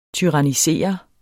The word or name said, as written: tyrannisere